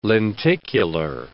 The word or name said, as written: lenticular